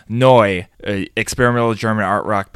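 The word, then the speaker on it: Neu!: I have to say it would be pronounced Neu because there's an exclamation point.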